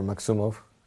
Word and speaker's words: Maksumov: So as my last name gives it away, my last name is Maksumov, or Maksumov. That's how most people pronounce it.